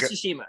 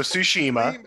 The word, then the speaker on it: Tsushima: Ghost of Tsushima. – Ghost of Tsushima.